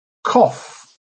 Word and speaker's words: cough: Cough, pronounced cough. This word is pronounced cough. And one way of remembering that, as we saw a couple of weeks ago, is with the expression cough drops.